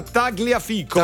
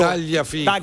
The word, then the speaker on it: Tagliafico: Sì, si pronuncia così. – Ma no, in Inghilterra. In Tagliafico. Non si è mai sentito un argentino dire GL? – Ma magari dicono Tagliafico.